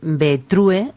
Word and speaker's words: Betrue: Betrue, se escribe así, Betrue mujer. Seguramente que van a escuchar decir Betru mujer. Pues este es el producto, Betrue mujer, un suplemento para la mujer en las distintas etapas de su vida.